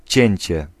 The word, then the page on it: découpage: France (Paris); [de.ku.paʒ]